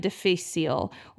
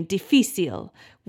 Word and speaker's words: difficile: So they gave it the Latin name difficile, which is hilarious. Um, you know, I think that's exactly how you say it in Spanish too. Something is difficult, it's difícil, and so it's clever, right?